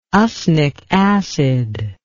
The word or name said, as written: usnic acid